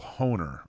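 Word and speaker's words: Hoener: So, all right, the winner is Michael Horner. That's H O E N E R. Michael Honer, I right. believe is how you pronounce that.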